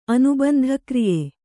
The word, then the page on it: ಅನುಬಂಧಕ್ರಿಯೆ: Kannada